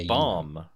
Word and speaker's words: Baum: No, I think it's B A U M. Oh, bomb. So it's just bomb.